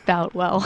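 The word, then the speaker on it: Boutwell: You know, Tony, we've heard several different ways of pronouncing it today. It's Boltwell.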